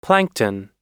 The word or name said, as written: plankton